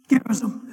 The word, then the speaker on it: Gerizim: Gerizim is how it's actually pronounced, but I call it Gerizim. That's how it's spelled.